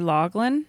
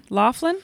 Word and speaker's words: Loughlin: Lori Loughlin. – Laughlin? – Is that how you say her name? – Lori Loughlin, the college scandal.